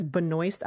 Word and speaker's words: Benoist: She says Benoist. I don't know why it's not Benoit. – Yeah. – It's spelled like it would be Benoit. The S throws you off because Benoit technically wouldn't have an S, but anyway.